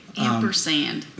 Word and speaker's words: ampersand: Okay, ampersand. – Am I saying it wrong? – There's no T in it.